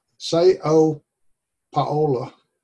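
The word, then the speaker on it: São Paulo: She lives in Sao Paulo. I think that's the way you pronounce it. Sao Paulo. Something like that. – That's perfect.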